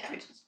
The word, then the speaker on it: habitus: It's spelled habitus, but of course in French they didn't say the H, they said habitus.